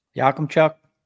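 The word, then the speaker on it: Yakemchuk: So we're looking at like Yamchuk. Is that how it's pronounced? – Yakumchuk. – Oh, Yakumchuk.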